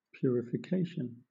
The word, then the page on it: purification: British English